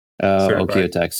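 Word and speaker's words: OEKO-TEX: Okay, so what does Blue Sign approved, and I'm going to just guess on the pronunciation, Ecotech, but OEKOTEX? – OEKOTEX, yeah.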